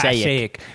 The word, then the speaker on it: Passaic: That's how do you say it? Passaic County. – Not Passaic. – Passaic. It's Passaic County.